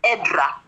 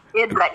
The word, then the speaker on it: Edra: My name is Edra. Edra, E-D-R-A.